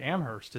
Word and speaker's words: Amherst: Now, I mean, apparently Amherst is how you say it. – Yeah, very good.